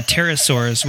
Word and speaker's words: pterosaur: and the pterosaurs, which are the flying reptiles. And there's a silent P in there, a pterosaur, right?